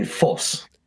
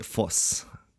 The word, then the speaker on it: Vos: I think the thing that saves Tipton is Vos. Um, he's very very Vosian, you know, and. Um, Don't you mean Foss? Uh, yeah, Foss.